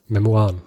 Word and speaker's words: Memoiren: Ja, der hatte das Ziel, später Memoiren, schreibt man, sagt man Memoiren. – Memoiren.